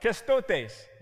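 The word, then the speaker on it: Krestotes (χρηστότης): First of all, the actual Greek word that is translated as kindness in the fruit of the Spirit passage is the word krestotes. It sounds Spanish, but it is not. It is Greek. I really like Spanish a lot. Krestotes. You say it like that.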